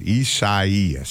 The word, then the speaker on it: Isaias: Isaias. – Mm-hmm. Okay. – That's how you pronounce it, Isaias.